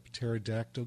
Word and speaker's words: pterodactyl: Do you want to know why you can't hear a pterodactyl go to the bathroom? – I don't know if I want to hear this. – Because the P is silent.